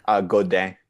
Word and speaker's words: Godin: Godin.